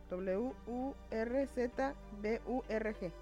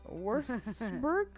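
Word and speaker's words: Würzburg: W U R Z B U R G. No puedo pronunciar eso. – Wurzburg Wurzburg Ok.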